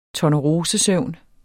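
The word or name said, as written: tornerosesøvn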